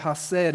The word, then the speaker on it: chesed: I just want to focus on one of those words in that description of God, and it's the Hebrew word chesed, C-H-E-S-E-D. It's pronounced chesed.